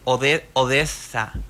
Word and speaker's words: ODESZA: Es Odessa, pero es O-D-E-S-Z-A.